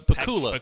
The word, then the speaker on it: Pakula: Am I saying his name right? Pakula. – It's Pakula.